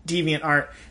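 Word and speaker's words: DeviantArt: So look out for that divine art. Deviant art. – This is the second time you pronounce it like that. – That's how I normally pronounce it. It's not even a flub.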